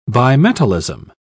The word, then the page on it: bimetallism: British English